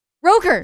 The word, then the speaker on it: Roker: It rhymes with a card game that you play that has, like, Full House and stuff like that. – Al. Roker.